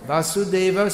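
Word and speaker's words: Vasudeva (वसुदेव): Vasudeva Sutam Devam. Kamsa Chanura Mardanam. Devaki Paramanandam. Krishnam Vande Jagadgurum. Anyone can say the first line? Vas, vas, vas is the first syllable. First word is Vasudeva. Vasudeva, Vasudeva.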